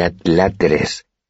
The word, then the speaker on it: adláteres: Hizo una pausa para enfatizar su moderada descripción, Vendrá con toda su corte de adláteres. Pronunció despacio la última palabra. ¿Se dice así? ¿Adláteres? Asentí y Willem puso cara de satisfacción.